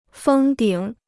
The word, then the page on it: 封顶: fēng dǐng